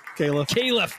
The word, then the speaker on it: Kalef: Kalef.